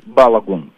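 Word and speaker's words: Balogun: Пишут, какое правильное ударение. Как правильно? Белагун, Белагун. Белагун? – Он говорит Балагун, да? Он говорит вот так, Флорен Балагун, да?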